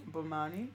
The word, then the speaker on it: Bomani: Pinky is for, how do I say his name? – Bomani. – Bomani Jones. Yes.